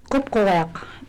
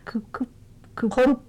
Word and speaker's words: Qupqugiaq: It's spelled in English Q-U-P-Q-U-G-I-A-Q. – Let me try that. – And that G has a dot over it, which is basically an Inupiaq symbol. Okay. Kupkuwak.